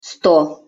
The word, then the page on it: sto: Russian